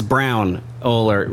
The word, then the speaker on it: Braunohler: It's Braunohler.